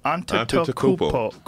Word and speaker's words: Antetokounmpo: How do you say that? – Antetokounmpo.